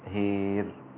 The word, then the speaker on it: هار: وهار أمال شعبة كلمة الألف من هار أصبحت هير هكذا، والإمالة هي الإضجاع أو الانبطاح كما يقوله بعض علماء التجويد في كتبهم. فالإمالة الكبرى هنا في هار فتصبح هير هكذا، ويصير النطق بها: على شفا جرف هير فانهار به في نار جهنم والله لا يهدي القوم الظالمين.